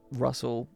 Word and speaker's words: Russell: Almost rhythmically, if I'm sort of saying my name to somebody, I'm like Mike Russell or Michael Russell double S double L.